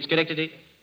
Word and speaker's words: Schenectady: Yeah, well, first I live in a place called Schenect Studies. – Schenect Studies. – Well, you mean Schenectady? – Yeah, how do you spell Schenect Studies?